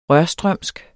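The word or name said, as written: RØRSTRØMSK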